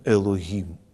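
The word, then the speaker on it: «элогим» (אלהים): Слово это произносится примерно, слово, которое здесь в русском переводе звучит как «бог», произносится «элогим».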